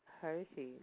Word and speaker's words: Hershey: Hershey, like chocolate kid.